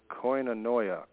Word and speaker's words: koinonia: The Greek word for communion is, and I'm not sure if it's right pronounced, it's koinonia, K-O-I-N-O-N-I-A, and that's actually meant fellowship.